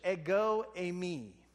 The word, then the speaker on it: ego eimi: But it starts with a phrase that is pronounced in English, ego eimi.